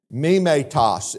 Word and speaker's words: mimetos: Mimetos is the Greek word, and that's how you say it, I'm not making it up.